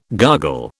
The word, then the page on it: gargle: British English